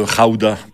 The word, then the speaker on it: Gouda: Le pays d'où vient le fromage, le gouda ou le raouda, parce qu'ils prononcent les G comme des R dans ce pays, c'est les Pays-Bas.